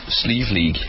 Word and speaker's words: Slieve League: Slieve League. – Slieve League, and Slieve means mountain.